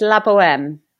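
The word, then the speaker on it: La Bohème: It's La Boheme.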